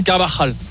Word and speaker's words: Carvajal: le mieux Carvajal, je trouve. – Tu peux nous le refaire? – Vas-y. – Carvajal.